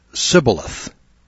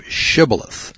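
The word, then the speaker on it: shibboleth (שִׁבֹּלֶת): That verse shows that Ephraimites had trouble pronouncing their H's, so they would mistakenly pronounce it sibboleth instead of shibboleth.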